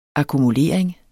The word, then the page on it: akkumulering: akumuˈleˀɐ̯eŋ; ɑku-